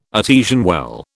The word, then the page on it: artesian well: British English